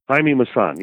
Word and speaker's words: Jaime Maussan: Jaime Maussan.